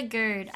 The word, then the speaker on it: GERD: Well, what's a GERD? I think that's how you pronounce it. G-E-R-D? Is that how you pronounce it? – Yeah, GERD? But it's not a word.